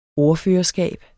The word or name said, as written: ordførerskab